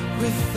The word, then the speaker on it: with: With every beat of my heart, I'll give you my love completely. My darling, this I promise you. – 首先我们来看英音的发音要点。我们来看第一句话。 With every beat of my heart, 这里面的 with, 尾部的这个 th 是可以发清辅音也可以发浊辅音。